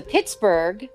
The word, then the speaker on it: Pittsburgh: So Pittsburgh, with that H, was actually, you know, a nod to that. And so this all led up to, in 1911, we actually appealed it and won our H back. – Wow. – My goodness. – That's a lot of work for an H. – Isn't it, though? Considering yeah. You don't even pronounce it.